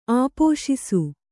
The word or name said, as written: ಆಪೋಶಿಸು